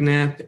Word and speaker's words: Knapp: Knapp. What did I say? – GN. – GN, so yeah. – GK is going to be on the silent K got me.